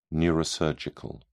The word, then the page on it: neurosurgical: British English